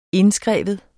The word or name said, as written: indskrive